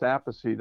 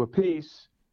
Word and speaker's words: Appice: Yeah, it looks like a piece, but it's always pronounced Appice. That's the way my father pronounced it. Carmine used to say Appice.